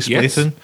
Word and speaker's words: Splatoon: Splatoon? – Yes.